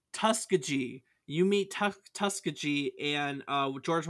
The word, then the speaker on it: Tuskegee: but it is Tuskegee.